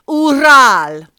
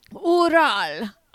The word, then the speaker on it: Ural: So can I tell you how Janneke told me how you do actually pronounce it? – Yes, please. – Ural. – Ural. Of course it is.